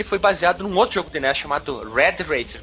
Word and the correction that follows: Rad Racer: pronunciei certo? – Rad Racer. Exatamente. Não, Rad Racer, Rad Racer.